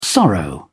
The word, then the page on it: sorrow: British English